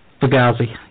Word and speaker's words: Fugazi: We are talking about Script for Jester's Chair, and Fugazi. I think that's how you pronounce it. – Fugazi. Yep.